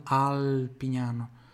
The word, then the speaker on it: Alpignano: Eu traço o português e pronuncia meio aportuguesado, mas em italiano, Alpignano.